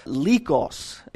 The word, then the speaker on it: Lycus: Now, there were other cities at that time that were named Laodicea, and so this one was often referred to as Laodicea on the Lycus, or I've heard it pronounced Lycos.